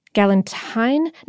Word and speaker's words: galantine: Galantine? No, it's spelled like Galentine's Day, but I think it's pronounced galantine.